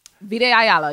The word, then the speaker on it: Vita Ayala: Vita Ayala, yes.